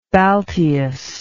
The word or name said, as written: balteus